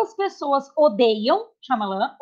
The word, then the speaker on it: Shyamalan: Sim, uh, eu, eu, tinha pensado em falar alguma coisa, é, eu não sei dire, direito pronunciar o nome dele, mas é Shyamalan? – É, Shyamalan. – É assim, Shyamalan.